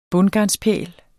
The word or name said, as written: bundgarn